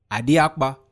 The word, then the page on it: adiakpa: Efik